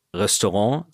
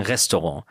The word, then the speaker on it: Restaurant: Ich habe ein paar Freunde, die auch in der Gastro arbeiten und die sagen nicht zum Beispiel Restaurant, sondern die sagen Restaurant. Die sagen auch nicht Dessert, sondern die sagen Dessert oder Patisserie. Also immer mit der Betonung auf der ersten Silbe.